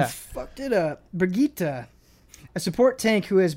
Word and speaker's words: Brigitte: In particular, they added a character called Bridget, a support tank. – Brigitte. – Brigitte, yes, thank you. – Is that how you pronounce it? Brigitte? – Yeah, it is.